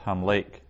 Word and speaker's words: hamlek: In the infinitive absolute, the final vowel is a tsere, so a long E, hamlek. So hamlik, infinitive construct, hamlek, infinitive absolute.